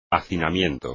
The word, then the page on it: hacinamiento: espagnol